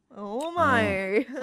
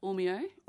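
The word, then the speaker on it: Ormeau: good old Ormo. – Ormo. – Sometimes pronounced Ormeo.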